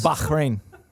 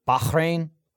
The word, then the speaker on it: Bahrain: Church's has over 1,700 locations around the world, including restaurants in, I don't even know how to pronounce this. – Bahrain. – Sure. Bahrain. Bawalrus.